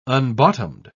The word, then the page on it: unbottomed: British English